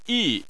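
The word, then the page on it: yi: tone 4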